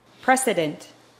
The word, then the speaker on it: precedent: precedent. precedent. – 선례, 관례. – precedent. 왠지 president하고 비슷하려다 발음이 말았네.